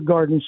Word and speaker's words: Gardyn: It's a smart hydroponic indoor garden system. It's called Garden. But it's spelled G-A-R-D-Y-N.